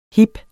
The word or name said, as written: hib